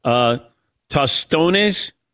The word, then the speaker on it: tostones: Tostones, is that the smashed fried plantains? – Sounds like you're pronouncing that right.